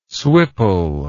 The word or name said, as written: swipple